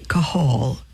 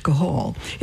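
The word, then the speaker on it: qahal: Jesus came to earth through the nation Israel, and the people of the Old Covenant were called a Kahal, Q without the U, Q-A-H-A-L, if we spell it in English, Kahal.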